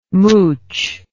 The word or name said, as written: mouch